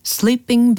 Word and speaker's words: slipping: Oh, I could feel the night slipping by 라고 했습니다. 이 끝부분에 slipping by 라고 들으셨는데 여기서는 잠자다의 sleep 이게 아니고 slipping by 짧은 발음이었어요. 철자는 S-L-I-P-P-I-N-G slipping by B-Y 네, 이 구가 되겠습니다.